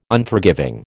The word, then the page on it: Unforgiving: American English